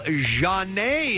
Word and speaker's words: Zhane: Janae is her name. Miss April. Hello, Janae. – Hi, how are you? – I'm great. I'm just happy I got your name right. Name right. Z-H-A-N-E. – Yes.